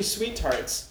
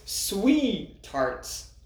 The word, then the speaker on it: Sweetarts: So today we have Sweetarts, extreme sour, chewy, and one interesting I noticed right off the bat is it's not actually Sweetarts. It's Sweetarts. There's only one T. – No, the T is split. It's the duality of man. – It's the opposite of silent T. It's like a double T.